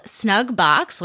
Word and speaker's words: Snugbox: Sponsor for this episode, I made friends with a company on Instagram called Snugbox, which rhymes with Drugbox,